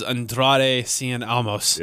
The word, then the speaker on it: Andrade Cien Almas: Is Andrade Cien Almas.